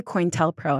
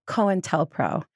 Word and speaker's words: COINTELPRO: so I always say COINTELPRO, and I've been saying it wrong my whole life. It's COINTELPRO.